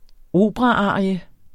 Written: operaarie

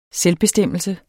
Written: SELVBESTEMMELSE

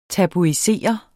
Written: tabuisere

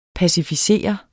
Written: pacificere

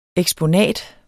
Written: eksponat

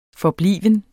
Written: forbliven

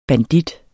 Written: bandit

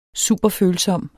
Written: superfølsom

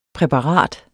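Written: PRÆPARATET

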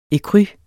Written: ecru

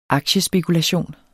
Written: aktiespekulation

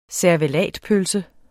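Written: CERVELATPØLSE

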